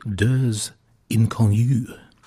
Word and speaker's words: Deux Inconnus: Nicky Nouveau at the start of that bracket with a French title, which I am reliably informed means two strangers and is pronounced Deux Inconnus.